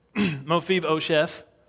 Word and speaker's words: Mephibosheth: Mephibosheth.